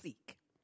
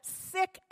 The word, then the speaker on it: Sikh: Now, I always thought the word was pronounced Sikh. She is Sikh and pronounces it Sikh.